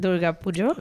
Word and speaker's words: Durga Pujo: Durga Pujo.